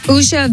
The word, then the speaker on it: Usha: Usha Vance, am I saying her name right? – Yes.